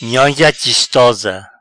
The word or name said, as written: Nhonha chistosa